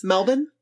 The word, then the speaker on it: Melbourne: It's pronounced Melbourne.